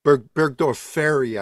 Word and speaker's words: Burgdorferi: In fact, it's considered that Borrelia burgdorferi is what is called Lyme disease, and that Burgdorferi, I think, is a better way to is the right pronunciation.